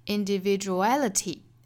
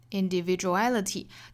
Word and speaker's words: individuality: 那么我们来看倒数第三个音节应该是 individuality，应该是 a 这个音节，所以我们把它重读 ality， ality 前面的相对弱读一些 individuality individuality，大家可以去查字典看一下这个词它的重读音节是不是落在 a，倒数第三个音节就是 a 这个音节了。